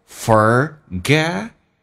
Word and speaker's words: forget: 第二个 I 不用发的像第一个那么的夸张，相对比较平一点，用点降调，是因为第一个 I 是主重元音，后面一个是 unstressed syllable 叫非主重元音，就变成 lifetime OK to forget someone， forget 的 t 去掉， forget someone。